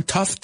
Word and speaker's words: tough: Tough, T-O-U-G-H. Vamos a pronunciarlo, por favor. Tough. Okay. Porque parece que estás entre thought, pensamiento, que entonces te faltaría una H al principio. But tough es lo que querías decir.